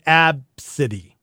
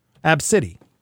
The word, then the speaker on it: Abcde: They it's A-B-C-D-E, but of course they pronounce it Absidy. Absidy. That's her name. It's actually kind of a cool name. – Absidy. – But why didn't they spell it A-B-C-I-T-Y? Why did they have to spell it A-B-C-D-E? – Yeah.